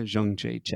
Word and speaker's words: Jędrzejczyk: Her name's a little hard to say here, but it's Joanna Jędrzejczyk. – Oh, Joanna Jędrzejczyk.